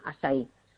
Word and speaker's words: açaí: Si lo queréis buscar escrito, que se escribe A con la cedilla, como si fuera esta portuguesa, y se llama eso acai orgánico, y se pronuncia açaí.